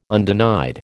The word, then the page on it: undenied: American English